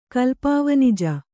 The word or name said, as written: ಕಲ್ಪಾವನಿಜ